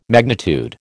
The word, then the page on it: magnitude: American English